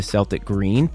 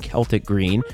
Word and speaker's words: Celtic: One, it obviously matched with the Celtics aesthetic, with the Celtic green, or the Celtic green, as is the best pronunciation of it.